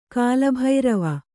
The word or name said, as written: ಕಾಲಭೈರವ